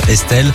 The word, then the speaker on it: Estl: Elle a enlevé quelques voyelles pour choisir son nom d'artiste, Estelle, mais ça s'écrit E-S-T-L.